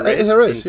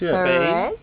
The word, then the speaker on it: Jerez: Yeah, Jerez. – Jerez in Spain. – With the J, not the H.